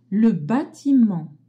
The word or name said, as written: batiment